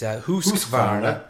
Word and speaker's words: Husqvarna: Well, personally, I would pronounce it Husqvarna. Davey seems to go with a deeper ooh on his Husqvarna.